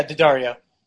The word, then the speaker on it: Daddario: I always forget how to pronounce the last name. I think it's Daddario. – Yeah, Daddario.